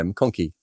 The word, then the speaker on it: McConkie: And it doesn't mean that Cole McConkle, is it McConkle or McConkie? I thought it was McConkie. Have I got his name wrong? How many Coles? Yeah, McConkie. Scared me there.